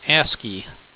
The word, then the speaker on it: ASCII: There are several ways to pronounce ASCII. Frequently, it is pronounced ASCII.